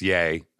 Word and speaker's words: Ye: I am sorry, it's yay. I was reading ye, but it's spelled Y-E, but it is yay.